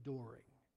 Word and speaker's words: Doring: And my last name is Doring. And it rhymes with it.